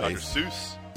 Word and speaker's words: Seuss: It's actually pronounced Soyce, so don't look at me funny for actually pronouncing it correctly. – Dr. Soyce. – I know that makes me the jerk guy, but it is Dr. Soyce.